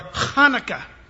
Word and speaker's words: Hanukkah: That is Cheslev 25th, or what we call Hanukkah, or Hanukkah. You got to spit when you say it if you say it right.